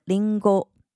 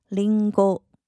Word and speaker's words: りんご: 首先是苹果，日文念成りんご，りんご，りんご，汉字写成菱形。